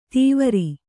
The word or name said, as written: ದೀವರು